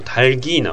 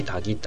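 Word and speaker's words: タ ル ギー (닭): だから正確に発音すると、うん、鶏がっていうとタルギーなわけですよね、はい、でも実際はタギタギって発音してるから、はい、まあ変化していってるってことでもありますよね。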